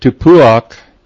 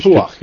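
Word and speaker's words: Tapuach (תפוח): And Tapuach is actually, that's how you pronounce it, I think, in Hebrew. – Tapuach, yes.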